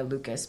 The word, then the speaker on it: Leucas: It's my name, Christiana Lucas, but it's spelled funny, LEUCAS.